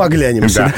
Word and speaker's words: оглянемся: И надо запомнить, что улыбнемся, но оглянемся.